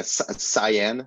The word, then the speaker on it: cyan: Maybe a cyan. Is that how you say it? – Nice. C-Y-N. Yeah.